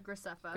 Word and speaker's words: Graceffa: Graceffa.